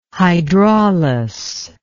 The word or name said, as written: hydraulus